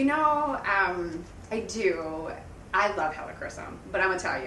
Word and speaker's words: helichrysum: Okay, so you just mentioned helichrysum. Is that what you said? Did I say that right? – Close. Helichrysum. – Helichrysum.